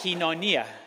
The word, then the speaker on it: koinonia: Help me. Say koinonia. That's the way. Say it like that. Kinonia. Kinonia. Okay, kinonia. Thank you.